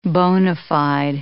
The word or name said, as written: bona fide